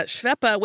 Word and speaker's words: Schweppe: Um, as demand for carbonated water grew in North America, uh, Schweppe, which I don't know when they started pronouncing it with the more American pronunciation of Schwepp.